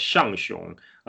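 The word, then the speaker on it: Zhang Zhung: Shangxiong is spelled Zhang and Zhung in English. It's a romanization of the old Tibetan, but a lot of English speaker probably has no idea how to pronounce it. So it's actually pronounced Shangxiong. In that way, the Chinese transliteration probably, it's much closer to the Tibetan reading.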